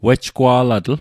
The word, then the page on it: wejgwa'latl: Mi'gmaq